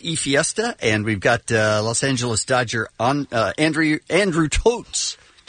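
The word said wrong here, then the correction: Toles: Did you say that right? – Andrew Tolles. It's his name.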